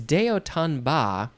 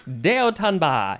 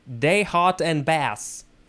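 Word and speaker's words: de haut en bas: Okay, if we wanted to pronounce this in English, American English, we would say day hot and bass. That's how I would say it.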